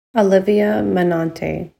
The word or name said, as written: Olivia Menonte